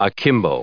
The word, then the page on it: akimbo: adjective